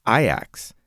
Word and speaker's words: Ajax: So Ajax is actually pronounced Ajax. – Ajax. – After a Greek mythological warrior.